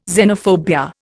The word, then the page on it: xenophobia: Indian